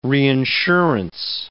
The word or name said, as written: reinsurance